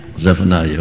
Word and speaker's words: Zephaniah: Is it Zephaniah? Am I saying it right? Zephaniah. Okay.